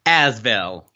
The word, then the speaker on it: ASVEL: Osvel? Or how do you say that team name? – Asvel. – Asvel. Right. You speak French. So Asvel.